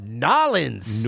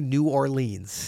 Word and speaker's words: New Orleans: You said it correctly, and you're not a native. I'm very impressed with that. – I've heard plenty of people from that area. – And they do not say New Orleans.